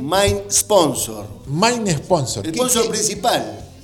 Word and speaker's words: main sponsor: No, en realidad se dice Main. – Claro, muy bien. Ahí está. Ahí está, díganos algo, ¿cómo se dice? – Main Sponsor.